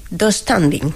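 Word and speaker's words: döstädning: Yo lo pronuncio... Dos standing. – Sí, así. Sí, tengo esa silla del Ikea. Sí, pronuncia así.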